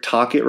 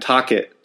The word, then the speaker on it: Tocchet: Then for all you fucking Canadian fucks that said I said Rick Tocchet wrong, I said his fucking name right. Come at me, bro. Send me the audio. I said his fucking name right. I'll say it right again. Tocchet.